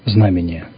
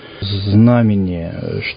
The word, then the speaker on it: знамение: И почему такое ударение? Иногда говорят знамение, ну, например, да? – Действительно, иногда путают. Вместо знамения говорят хор знамения.